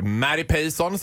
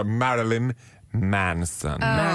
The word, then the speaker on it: Marilyn Manson: Ja, jag var ju på Annexet i Stockholm och såg Mary Payson, som min mamma kallar honom, eller Perry Mason, hon har lite svårt att uttala, Marilyn Manson.